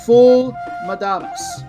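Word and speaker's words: Ful medames: We are going to look at the national dish of Sudan, unofficially called, I'll spell it first, F-U-L and then M-E-D-A-M-E-S. I believe it is best pronounced Ful Madames. Even though it's got that E, it's more of a moo sound.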